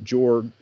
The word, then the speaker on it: Jorg: And you don't even have until 1924, in the aftermath of the Ape Canyon event, a writer named, some people pronounce it Jorg, or some people pronounce it George, but it's J-O-R-G, but he was essentially a Clallam Indian.